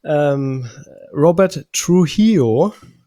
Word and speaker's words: Trujillo: Robert Trujillo von Metallica, so wird der Name ausgesprochen.